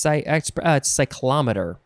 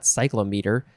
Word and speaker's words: cyclometer: It's cyclometer. That's how you pronounce it. Not cyclometer. Cyclometer.